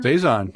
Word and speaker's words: saison: It was a raspberry, am I saying this right? Saison? – Saison, yeah. Yeah.